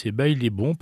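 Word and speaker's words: Baile Bom: Je me demandais comment ça se prononçait. Eh bien, parce que ça s'écrit B-A-I-L-E puis B-O-M. Donc voilà, je disais Baillet Bon, machin. Non, non, c'est Baillet Bon parce que quand le bal a été bon, on dit que le bal est bon.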